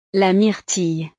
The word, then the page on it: la myrtille: frances